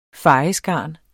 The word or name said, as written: fejeskarn